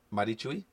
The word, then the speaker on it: Marichuy: Marichuy? Yes, I said that right.